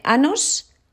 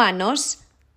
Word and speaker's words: años: Y yo le dije, ¿estás seguro que tienes 27 años? Anus, en inglés. Cuidado con esta pronunciación. Años, no anos, años. Si decimos anos, nos referimos a algo muy diferente.